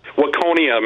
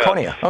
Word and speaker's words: Waconia: Am I saying it right? – Waconia, Minnesota, yeah. – Waconia, okay.